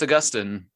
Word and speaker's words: Augustine: So how do you pronounce his name? – Oh, it's Augustine.